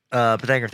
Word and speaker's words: Pythagorean: Pythagorean theorem.